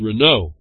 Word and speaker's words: Renault: kind of N-A-U-L-T, reminds me of Renault, and they found out later in my young life, you don't pronounce the alt, so it's Renault.